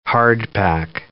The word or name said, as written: hardpack